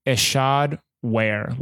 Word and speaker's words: Ishod Wair: So you pronounce it Ishod Wair, like you wear clothes, and the first name is pronounced Ishod.